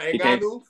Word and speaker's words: Ngannou: I can never pronounce his name right. – Oh, Ngannou? Francis Ngannou? – You would have to fight him.